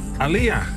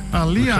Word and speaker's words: Alia: hoe spreek je het eigenlijk uit? – Alia. – Alia. – Geleerd van Jeroen vorige week. – Ja, dat is inderdaad. – Ik zei Alia, maar dat is iets anders.